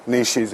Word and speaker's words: niches: Niches in pieces. No, it's got to rhyme with quiche, not kitsch.